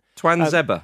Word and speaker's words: Tuanzebe: Tuanzebe, is that how you pronounce it? – I think so, yeah. – Tuanzebe. – We know what you meant. – Tuanzebe, not Tuanzebe.